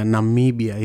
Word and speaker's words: Namibia: And I learned something about his country, Namibia. Is that the correct pronunciation? – Yeah, Namibia, yeah.